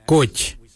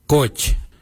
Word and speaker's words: Goodj: Kairi is spelled G-I-I-L-Y-I, kairi. For the traditional stone axe, we say koch, koch. Koch is spelled G-O-O-D-J, koch.